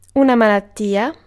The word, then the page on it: disease: /dɪ.ˈziːz/; /dɪ.ˈziz/